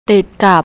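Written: ติดกับ

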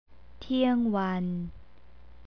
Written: tiangwan

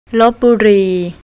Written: lopburi

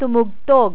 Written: tumugtog

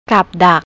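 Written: กับดัก

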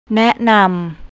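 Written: แนะนํา

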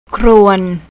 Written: krown

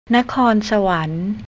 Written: nakorn

